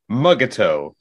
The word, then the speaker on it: Mugato: My favorite pronunciation from the episodes was Mugato.